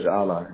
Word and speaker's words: Ala: My name is Ala.